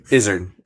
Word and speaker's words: Izzard: Well, it's like, did you ever see that stand-up with Eddie Izzard or Eddie Izzard? I don't know how you say his name. – Izzard.